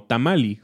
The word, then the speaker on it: tamale: Y me parece muy interesante porque hoy en día en inglés lo escriben como tamale y lo pronuncian como tamali, justamente igual que en náhuatl.